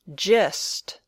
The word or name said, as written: gist